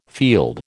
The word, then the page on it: field: American English